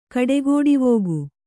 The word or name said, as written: ಕಡೆಗೋಡಿವೋಗು